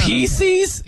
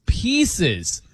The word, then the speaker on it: Pieces: Yeah, that's Pieces? Okay. He hit me with that, and I'm like... I'm like, how do you say it? He's like, Reese's Pieces. You don't say pieces. You say pieces.